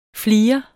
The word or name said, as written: flire